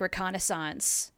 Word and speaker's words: reconnaissance: I'd have to actually get into the dictionary and look for words that sound like reconnaissance that mean. Gathering information. – Yeah, I said it right. Reconnaissance. Yeah.